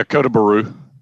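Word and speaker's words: Kota Bharu: And on December 7, 1941, before the Japanese amphibious landing on Kota Baru, is that how you say it? – How do you spell it? – Kotabharu. Kota Baru? – Yeah, Kota Baru. – And is how I would. Malaya, M-A-L-A-Y-A. Kota Baru, Malaya.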